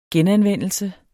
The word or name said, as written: genanvendelse